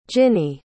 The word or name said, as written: ginnie